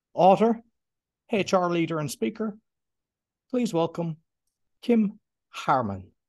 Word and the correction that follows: Hamer: Did I say it correctly? Your surname? – Hamer. – Hamer.